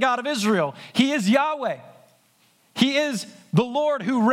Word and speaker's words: Yahweh: If you look at it in your Bible, you'll see 14 times, all capital letters, L-O-R-D, Lord. That's the personal name of God, Yahweh, is how it's pronounced.